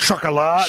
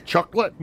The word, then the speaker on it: chocolat: And it's pronounced chocolat. – Chocolat. – Not chocolate.